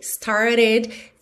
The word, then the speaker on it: started: Lembrando que esse E aí da palavra started é pronunciado, ok? E esse T, o segundo T, started, ficou reduzido para rarara, muito comum na pronúncia americana, também poderia ser started, alright? – Então, I remember when we first started.